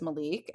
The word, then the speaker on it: Malik: And the only people that I really remember on the red team are Malik, which I'm learning that's how you said his name. – Mm-hmm. – It's spelled really interesting, but it's Malik and he's really cute.